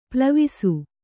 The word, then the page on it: ಪ್ಲವಿಸು: plavisu